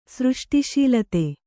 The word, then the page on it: ಸೃಷ್ಟಿಶೀಲತೆ: Kannada